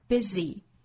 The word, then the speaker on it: busy: 这个词我们注意啊，读成 easy, easy, easy, 很多以字母 y 结尾的单词都是发这个紧张的，但是是短音的 e 的发音，像 busy, b-u-s-y, busy, busy, busy。再比如说 many, m-a-n-y, many, many, many。